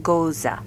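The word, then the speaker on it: Gose: I don't think I've ever In German, they pronounce that Goza.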